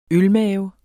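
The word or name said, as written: ØLMAVER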